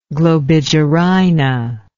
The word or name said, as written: globigerina